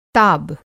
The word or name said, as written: ताब